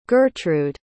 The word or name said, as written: gertrud